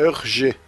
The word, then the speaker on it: Hergé: Então, o RG em francês, a pronúncia é Hergé.